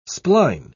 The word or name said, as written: spline